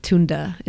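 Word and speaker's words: Tünde: So it's T-U-N-D-E, two umlauts over the U. Excuse me. And so it's actually really pronounced like Tunde, but I'm a bit Americanized, so Tunda is how, like, I've been saying it.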